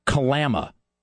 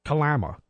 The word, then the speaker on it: Kalama: Oh, I'm sorry. It's Kalama. – Kalama. – That's how it's pronounced. A small town. It's called Kalama.